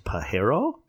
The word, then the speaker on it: Pajero: Mitsubishi has, I believe it would be pronounced Pajero, P-A-J-E-R-O, which means jerk in Spanish.